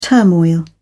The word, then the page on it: turmoil: British English